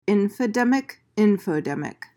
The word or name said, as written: infodemic